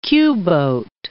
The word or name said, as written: Q-boat